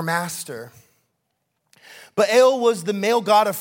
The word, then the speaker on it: Baal: The first one we're going to talk about is probably the most prominent, and his name is Baal. Yes, I'm saying that right.